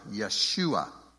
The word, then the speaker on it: Yeshua: Yud, Shin, Vav, Ayin, Yeshua.